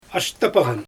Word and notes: ashtypaGan: Xyzyl

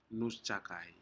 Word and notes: nusjakai: Aneityum

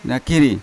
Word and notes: nakiri: Yawa

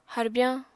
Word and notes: harbyañ: apatani

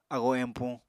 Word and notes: agoo empuñ: Apatani

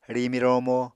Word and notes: rimi-romo: Apatani

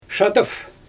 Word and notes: ʃadɯf: Xyzyl

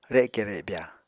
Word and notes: reke rebya: Apatani

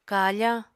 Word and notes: kafañ: Apatani

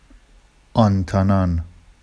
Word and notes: ɑntɑnɑn: Vafsi

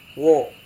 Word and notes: wo: Serewen

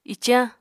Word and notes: icañ: apatani

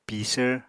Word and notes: pisvr: Apatani

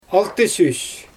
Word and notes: altysüs: Xyzyl

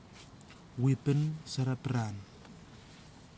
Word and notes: wipin sarapiran: kwamera

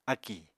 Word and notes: akì: apatani

